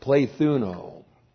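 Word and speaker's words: plethuno (πληθύνω): And the key word in that phrase is the Greek verb plethuno. Plethuno. Looks like that in the Greek. That's P-L long E-T-H-U-N long O. Plethuno.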